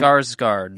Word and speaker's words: Skarsgård: Skarsgård.